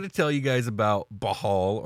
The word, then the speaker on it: Bhaal: Is it Bhaal or is it Bhaal? – So it's Bhaal. That's the only pronunciation I've ever seen.